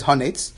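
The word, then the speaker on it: Hanetz (הנץ): B-U-D. The sun starts to come up. So people say this wrong. The real way of pronouncing it is Hanetz.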